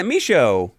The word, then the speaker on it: Michaud: You pretty much got it, Michaud. Yeah. – Michaud. – Silent D. The D silent is D. Silent and deadly.